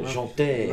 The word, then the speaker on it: Jaunteé: It's got the accent on the E, so Jaunteé.